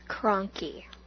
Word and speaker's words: Kronke: Kronke. – Kronke. – Yes. – Kronke. – It's super German, and I guess when you pronounce it correctly, it's supposed to be cranky, which is no end of jokes for my family.